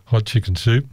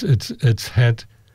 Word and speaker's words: Hatshepsut: when we were told there how to pronounce it, our guide said hot chicken soup. It's not far off how you should pronounce it, but hot chicken soup. But it's Hatshepsut. But it sounds a lot like hot chicken soup, doesn't it?